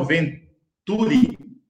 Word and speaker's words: Venturi: Nós tínhamos uma candidata como representando os trabalhadores e trabalhadoras, que é a doutora Isabel, doutora Isabel Venturi. Falei certo, Júlio? Isso.